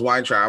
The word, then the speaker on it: Weintraub: She goes, Weintraub.